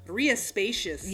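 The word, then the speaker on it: Rías Baixas: It's Ria Spacious. – Yeah. Sounds like a spaceship. – Yeah, but it's pronounced, it's got like a big, did you hear me say a B? Because there's a B in there.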